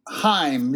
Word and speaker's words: Chaim: I think he pronounced it Chaim. – Chaim, that's right. No C. Yeah, my bad.